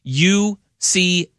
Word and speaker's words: Uche: Uche. – Oh, Uche. – Is it Uche? – Uche, yeah, okay.